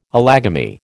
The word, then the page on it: allogamy: American English